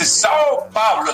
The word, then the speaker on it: São Paulo: Dice São Paulo.